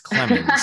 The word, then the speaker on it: Clemons: Clemons. Mm. Oh, C-L-E-M-E-N-S. No. Oh, two M's. No. Oh, E-N-T-S. No, it's not Clements. It's Clemons.